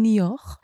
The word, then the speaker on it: Niort: Ich merke mir das so, dass es sich anhört wie New York, aber irgendwie französisch ausgesprochen Niort, ist aber nicht New York, ist wie gesagt in der Campagne, bedeutet auf dem Land.